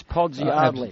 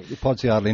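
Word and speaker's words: Podsiadly: Why is A-D-L-Y pronounced Ardley? – Is he from Adelaide? – Yeah. – Because I heard go to Adelaide. – Commentators going with Podsy-Ardley. And I thought, what's the logic? – Here's another question for you.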